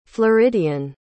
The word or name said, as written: floridian